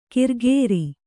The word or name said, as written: ಕಿರ್ಗೇರಿ